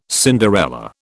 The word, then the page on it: Cinderella: British English